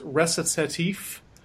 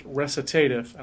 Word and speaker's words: Recitatif: And if I keep mispronouncing it, it's because I was always told that story was pronounced recitative, and I think it's recitatif. – Yeah, the second way there is how I was taught to pronounce it, but I might have also been taught wrong.